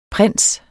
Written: prins

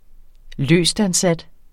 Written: løstansat

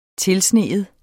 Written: tilsnige

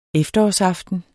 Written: efterårsaften

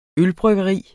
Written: ølbryggeri

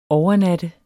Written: overnatte

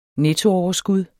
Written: nettooverskud

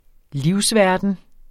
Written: livsverden

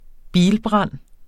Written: bilbrand

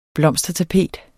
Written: blomstertapet

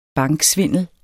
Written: banksvindel

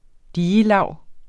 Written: digelag

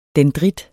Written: DENDRIT

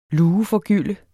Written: LUEFORGYLDT

